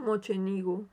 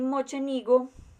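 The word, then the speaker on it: Mocenigo: Al regresar a Alemania, Giordano recibió una carta de un noble veneciano que se llamaba Giovanni Moque, Moquenillo, ¿cómo, se pronuncia eso? – Mochenigo. – Giovanni Mochenigo, quien mostraba un gran interés por sus obras y le invitaba a trasladarse a Venecia para enseñarle sus conocimientos.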